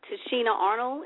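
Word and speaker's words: Tichina Arnold: And so I also, Tashina Arnold, if I'm saying her name correctly, that was on Martin. – Yes.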